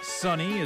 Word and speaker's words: Sonny: Sunny is spelled S-O-N-N-Y.